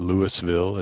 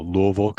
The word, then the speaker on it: Louisville: And uh, yes, that's how you say it. It's not Louisville. It's not Louisville. You just kind of mumble it and it, it rolls off your tongue. So Louisville, Kentucky.